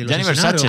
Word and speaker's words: Gianni Versace: Gianni Versace.